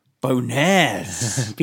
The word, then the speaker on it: Bonerz: This guy's name is Peter Boners. And do you want to know how he spells his surname? – How does he spell it? – B-O-N-E-R-Z. – Boners.